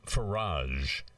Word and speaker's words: Farage: There's a very popular, a notorious member of parliament, Nigel Farage, F-A-R-A-G-E, rhymes with Quel Dommage.